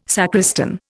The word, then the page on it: sacristan: Indian